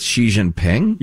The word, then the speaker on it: Xi Jinping: I go with Xi Jinping. – You're going with Xi. – Xi. Kind of half between.